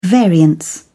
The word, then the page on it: variance: British English